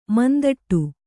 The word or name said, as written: ಮುಂದಿಡು